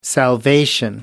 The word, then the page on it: salvation: British English